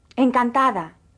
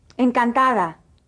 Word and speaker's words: Encantada: Listen carefully again to the pronunciation of the D in this word. – Encantada. Encantada. – The D, when in between vowels, is pronounced like the TH in the English word the.